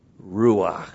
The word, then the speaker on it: ruach: but by means of Ruach. R long U A C H.